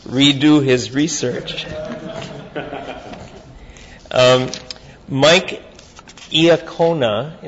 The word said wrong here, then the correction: Licona: I-A-C... – Oh, it's Licona, L-I-C-O-N-A. I'm sorry, yeah. It is Licona, yeah.